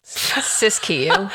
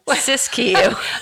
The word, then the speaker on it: Siskiyou: In the Siskiyou. Saskatchewan. – Siskiyou.